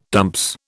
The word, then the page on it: dumps: British English